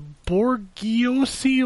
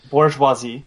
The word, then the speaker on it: bourgeoisie: made me question if you belong to the bourgeoisie or proletariat? How do you say that? – Bourgeoisie.